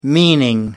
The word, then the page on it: meaning: American English